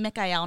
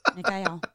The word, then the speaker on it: Michael: And it's like, I heard some British guy call him Mikael, and I'm like, that makes so much fucking sense. And it's not how that's how I'm going to pronounce Michael forever now. Mikael.